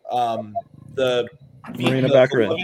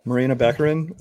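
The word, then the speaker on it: Morena Baccarin: Marina Baccarin. – Interest in Deadpool. What's her name? – Marina Baccarin or Baccarin, depending on who you ask.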